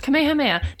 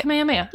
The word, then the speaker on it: Kamehameha: Kamehameha. That's not bad. – Yeah. No, you got it. – Kamehameha. – You got okay. – it. – Cool. Yeah. Go pay your respects to King Kamehameha.